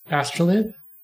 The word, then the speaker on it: astrolabe: Astrolabe.